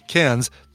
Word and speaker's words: Cairns: Yeah, no, lucky Phil, I know you're saying it's pronounced Cairns. Take it as an Aussie, Cairns. That's how you pronounce it.